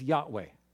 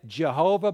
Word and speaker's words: Yahweh: But the name that's that's the covenant name of God, the covenant God of Israel, is Yahweh. We used to say it Jehovah back in the day. For generations, we said it Jehovah, but I think we've learned a little bit more about the correct pronunciation.